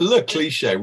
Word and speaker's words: Le Cliché: I thought it was Les Cliches. That's fantastic. Le Cliché. Welcome aboard.